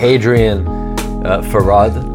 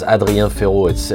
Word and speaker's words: Hadrien Feraud: Hadrien Feraud. That's how it would be pronounced if I was from here. – Okay. – In France, it sounds Hadrien Feraud. It's rather boring.